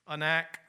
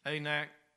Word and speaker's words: Anak: Anak. Anak. Pronounce it either way you want to. Like salmon. You pronounce it salmon if you want to. That fish don't care what you call it, as long as you know what it is.